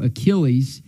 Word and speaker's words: Achilles: Instead of saying mythological hero Achilles, he said mythological hero Achilles. And he didn't get credit for the puzzle because he couldn't pronounce Achilles right.